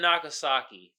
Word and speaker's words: Nagasaki: Hakusaki, or, am I pronouncing that right? I think I'm pronouncing it wrong. Let me get it right. It was in Japan, basically. They dropped on Japan, but the two cities that they dropped it on. Hiroshima and Nagasaki. There it goes, right there. I knew I was pronouncing it wrong.